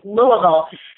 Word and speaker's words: Louisville: Well, I'm from Southern Indiana, so I know how to pronounce it. Right, it's Louisville, correct? – It perfectly, yeah. Perfect pronunciation is Louisville.